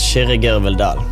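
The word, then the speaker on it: Kyrre Gørvell-Dahll: My real name is pronounced Shiri Girvaldal.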